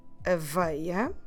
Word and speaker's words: aveia: So the word for oat is aveia. Aveia. Como se escreve aveia? A-V-E-I-A. Aveia. I know, many vowels in a row, right?